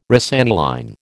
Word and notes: rosaniline: American English

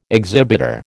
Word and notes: exhibitor: American English

